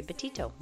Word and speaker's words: Betito: If you want to find me on social media, at Dr. Lori Betito. My last name is spelled B-E-T-I-T-O.